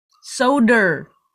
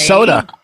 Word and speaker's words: Soder: Soder. – Soder. Who the fuck says Soder? – Yeah, I know. It's Soda.